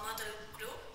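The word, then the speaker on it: Mötley Crüe: Algunos ejemplos de estos movimientos son bandas como Motel Crue, lo dije bien.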